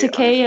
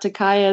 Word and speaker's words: Takei: What is it? He says Takei as in pay, not Takei as in pie? Or is that wrong? – That sounds right. – Something like that. – In terms of the vowels? – Yes, that is correct.